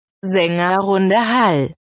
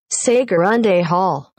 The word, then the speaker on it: Sängerrunde: So here's, according to Google, here's how you would say it if you're German. – Sängerrunde Hall. – Sängerrunde Hall. – Very good. – Okay. If you're American, if you're doing an English-speaking person, you would say it like this. – Sängerrunde Hall. – Well, it's very Texan.